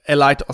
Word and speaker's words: Allied: von Metal of Honor Allied Assault. Ich sage immer Elliot Assault. So habe ich es als Kind ausgesprochen, Elliot Assault.